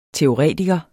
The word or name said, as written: teoretiker